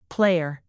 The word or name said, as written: player